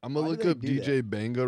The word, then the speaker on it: Mbenga: DJ Mbenga was bad. Kwame Brown was bad. – You know it's just DJ Benga? – It's not Mbenga. No. It's the M is silent.